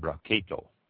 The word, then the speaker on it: Brachetto: Barchetto is spelled B-R-A-C-H-E-T-T-O. Pronounce it Barchetto.